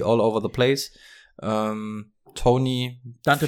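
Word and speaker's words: Toney: Ich finde, ähm, auf welchen Namen einigen wir uns jetzt? Tonney? Äh, wie, wie nennen wir ihn jetzt? – Kadarius Tony. – Tony, Tony. – Also so Tony. wurde er überall ausgesprochen in den USA.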